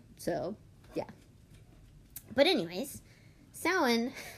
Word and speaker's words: Samhain: it's spelled Samhain, and a lot of people pronounce it Samhain. It's not how it's pronounced. It's pronounced Samhain. – Why? – I don't know, because it's Celtic.